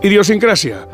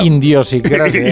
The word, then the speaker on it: idiosincrasia: Idiosincrasia. Ahí suele caer una idiosincrasia. – Indiosincrasia. – y una indiosincrasia. – Ah, pero esto se pronuncia mal siempre.